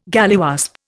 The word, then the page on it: galliwasp: Indian